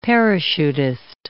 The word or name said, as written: parachutist